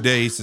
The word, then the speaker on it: Deace: Steve Dace, it's spelled Deace, D-E-A-C-E.